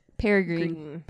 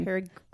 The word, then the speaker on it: peregrine: Peregrine? – Peregrine. – Peregrine. Then why don't they spell it G-R-E-E-N? – How is it spelled? – P-E-R-E-G-R-I-N-E.